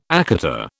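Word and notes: écarté: British English

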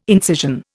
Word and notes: incision: Indian

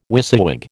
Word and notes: WYSIWYG: American English

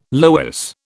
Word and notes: loess: British English

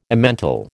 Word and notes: Emmental: American English

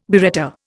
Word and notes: biretta: Indian

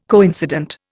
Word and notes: coincident: Indian

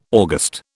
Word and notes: August: British English

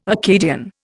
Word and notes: Akkadian: Indian

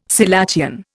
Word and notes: selachian: Indian

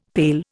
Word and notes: pail: Indian

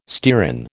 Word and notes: stearin: American English

